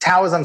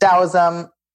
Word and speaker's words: Taoism: Some people pronounce it Taoism. Some people pronounce it Taoism. Tao Te Ching. Both pronunciations, I think, are okay.